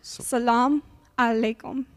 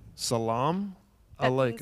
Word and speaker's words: Salam alaikum: And you're like, How do you say that again? – Salam alaikum. – Salam alaikum. – That means peace. Alaikum. Yes. Alaikum. Upon you.